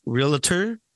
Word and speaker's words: realtor: Is it pronounced realtor or realtor? – Realtor. So many people get this mixed up. Realtor.